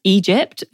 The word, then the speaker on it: EDGP: Before she left high school at 16, she formed a female rap duo called, it's pronounced Egypt, but written E-D-G-P, apparently, which sounds very high school.